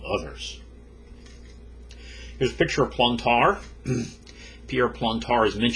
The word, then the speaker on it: Plantard: We're going to be looking primarily at Pierre Plantard. The final D in his name is not pronounced in French.